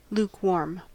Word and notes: lukewarm: American English

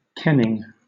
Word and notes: kenning: British English

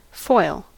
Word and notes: foil: American English

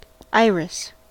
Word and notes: iris: American English

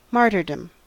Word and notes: martyrdom: American English

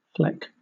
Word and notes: fleck: British English

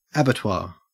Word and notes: abattoir: Australian English

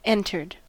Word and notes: entered: American English

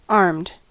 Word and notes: armed: American English

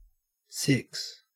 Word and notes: six: Australian English

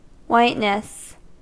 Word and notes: whiteness: American English

